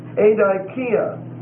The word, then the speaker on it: adikia: The next word is adikia. Adikia. A-D-I-K-I-A. A-D-I-K-I-A.